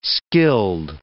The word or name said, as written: skilled